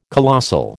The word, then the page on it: colossal: American English